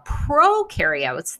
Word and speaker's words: prokaryote: Now, prokaryotes, that word pro can kind of rhyme with no, they do not have a cell.